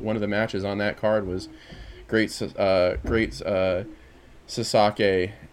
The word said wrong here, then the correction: Sasuke: and Did I say his name wrong? I know. – Sasuke. – Great Sasuke.